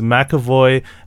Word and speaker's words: McAvoy: McAvoy,